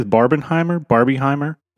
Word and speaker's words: Barbenheimer: And what is, how do you say this? Barbenheimer? – Yeah, it's the phenomenon as it's become is Barbenheimer. So it's like a mashup of Oppenheimer, Barbie.